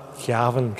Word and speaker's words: Cavan: As a Cavan man, Cavan, get the pronunciation right, you grew up with the experience of life, yes, in a border county and a border diocese of Kilmore.